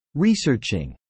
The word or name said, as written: researching